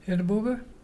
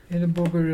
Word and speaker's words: Irrabuga: Edibuga. Edibuga is a dolphin. You spell it I-R-R-A-B-U-G-A.